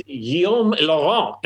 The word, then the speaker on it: Guillaume Laurent: I think it's Guillaume Laurent, and I don't even speak French. But there you go. I pronounce the L's. I should know better. I pronounced the L's, and that was just terrible.